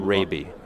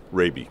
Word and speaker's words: Rabe: Yeah, I'm Raby is how it's pronounced. – Yeah, Raby, got it.